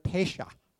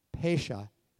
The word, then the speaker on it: pesha: And in Hebrew, it's the word pesha. Pesha, you'd spell it P-E-S-H-A if you're writing it out in English. So that's pesha.